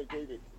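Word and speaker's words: Kvívík: Ja, vi, altså jeg bor i Kvivik, men, men vi, vi, vi dekker hele, vi dækker hele kan man sige, surstremer og så øh, Asteroy også.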